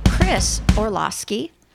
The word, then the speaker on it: Chris Orlowski: Chris Orlowski, thank you for sticking around.